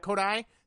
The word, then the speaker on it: Kodai: The only other big Cubs thing I had is there is now some talk that Kodak Senga, if I'm saying his name right, I hope I am. – Kodai. – Thank you. Oh, you know what? My autocorrect changed it to Kodak.